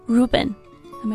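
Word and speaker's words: Reuben: 九老师用了比较高级的一个词啊，叫 moniker，其实就是名字的意思啊，name。那么小男孩呢，可以取一些以 R 或者 TH 开头的名字，比如说 Ruben。Am I pronouncing it right? – Right.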